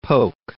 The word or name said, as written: polk